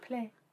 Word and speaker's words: plein: Everyone's it's actually pronounced play.